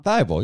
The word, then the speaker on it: Thybulle: I can't say his name right now. – Thybulle, yeah, you're good.